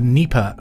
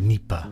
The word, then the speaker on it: Dnieper: The Dnieper River or the Dnieper River. It's a silent D, isn't it?